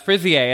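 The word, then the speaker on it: fraisier: And she made a frisier, and I still think I'm saying that wrong, but she said I'm saying it right.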